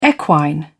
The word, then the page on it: equine: British English